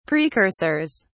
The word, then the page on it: precursors: British English